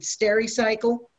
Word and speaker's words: Stericycle: Is it Stericycle? for the pickup, Chief? – Yep, that's correct.